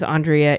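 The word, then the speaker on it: Aundrea: Her name is Andrea and she spells it A-U-N-D and everything else the same.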